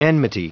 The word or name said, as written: enmity